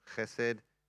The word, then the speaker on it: chesed: Other spellings, this is the way I normally spell it, chesed, or I've seen it spelled like this just recently, chesed. Chesed. So when you say the ch, you've got to sound like you're clearing your nose.